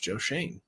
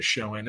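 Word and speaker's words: Schoen: By the way, producer Adam says Joe Shane is now his name is pronounced Joe Shane, is what the Giants say. Other people say Shoen.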